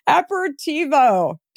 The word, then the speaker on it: aperitivo: Aperitivo. Did I get it right? You did. You got it right.